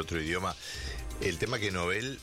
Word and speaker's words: Nobel: Dice que así se pronuncia el apellido de su creador, Alfred Nobel, en su idioma original, el sueco. A pesar de que en español mucha gente pronuncia erróneamente como una palabra grave, incluido yo, la Real Academia recomienda mantener la pronunciación original aguda de la palabra Nobel.